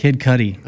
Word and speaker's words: Kid Cudi: So we got a song we're going to, Kid Cudi. I don't even know. Is that how you say it? – Kid Cudi.